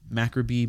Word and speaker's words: macabre: No, we told you how to say it, and then you just said a different mispronunciation. – You said corn. – All right, let me start over. – You said corn on the cob. – Black stories are tricky, morbid, macabre mystery stories. – You said it wrong again. – Oh, what is it? – Macabre. – Got it, macabre.